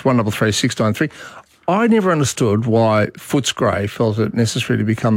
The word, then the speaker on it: Footscray: Pronounce it properly. It's Footscray.